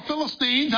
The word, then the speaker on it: Philistines: The Philistines, it goes on to say, or Philistines, however you want to pronounce it, talk to Delilah and say, Samson is hooked on you.